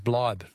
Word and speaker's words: bleib: Sorry to correct your German pronunciation, but stay in German is Bleib, I believe.